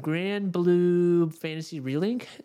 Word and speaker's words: Granblue Fantasy Relink: Granblue Fantasy Relink. Is that the name of it? Did I say that right? – That is indeed.